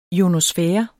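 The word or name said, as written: IONOSFÆREN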